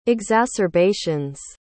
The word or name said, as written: exacerbations